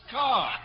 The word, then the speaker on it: Kerr: And I include Douglas Fairbanks, Jr. and Deborah Kerr, pronounced Carr.